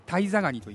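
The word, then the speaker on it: 間人ガニ: タイザガニと言います間という字に人と書いて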